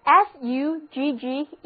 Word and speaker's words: suggest: 那么，如果我们在 gest 之前加上表示下面的前缀 SUG，又出现了一个新的单词 SUGGEST，字母组合 GG 读作 G，重音放在第二个音节上，这个单词就读作 suggest。